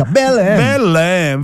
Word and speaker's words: Belém: Belém.